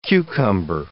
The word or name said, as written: cucumber